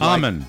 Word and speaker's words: Eamonn: U-R-E-Y. – Yeah, I don't like that name. – But I thought E-A-M-O-N-N would be Amon. – I thought so, too, but his wife, girlfriend, says it's pronounced Amon.